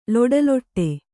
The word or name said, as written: ಲೊಡಲೊಡ